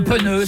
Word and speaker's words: pneu: Mais oui, dans le sud, c'est un pneu, c'est P-E-N-E-U, c'est pneu. Bien sûr, c'est comme ça que ça se prononce, c'est comme ça que ça s'écrit, P-E-N-E-U, pneu.